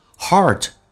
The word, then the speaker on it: heart: I wrote your name in my heart，刻在我的心里面， heart 重度卷舌在美式发音，那这个发音其实你无法打开到嘴巴极限，叫 r，不是这样子的，它有点近似于发澳大利亚的 o 这个发音，你看 o 在这个基础上卷舌，叫做 r Heart，因为你无法达到极限，在这个情况下再进行重度卷舌，所以发 a 再加 r 叫 heart。